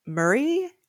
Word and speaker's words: Maury: I mean, it's like Maury, which is pronounced Murray, which sounds way less talk show.